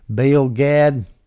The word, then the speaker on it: Baal Gad: Now, what is Baal Gad, or Baal Gad, depending on how you want to pronounce it? We're going to say Baal because that's how we do it down here in the south.